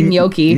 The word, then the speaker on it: gnocchi: People in Canada pronounce it, or we're ordering in restaurants, gnocchi. – I call it gnocchi. – It is gnocchi. – Gnocchi. – I say gnocchi. – Gnocchi? – The pronunciation they give me here is gnocchi.